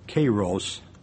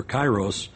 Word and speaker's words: kairos: In the Greek, it's pronounced kairos, or kairos, means fulfilled and at hand, indicate an immediacy and a sense of urgency.